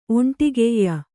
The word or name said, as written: ಒಂಟಿಗೆಯ್ಯ